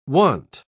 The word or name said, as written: want